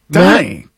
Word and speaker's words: Dunny: D-U-N-N-Y. – A Dunny? – I do not know what this is.